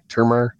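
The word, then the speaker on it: Termarr: Is it Termar or Termar? Do you know how to say his name? – Termar. – Exactly. Termar.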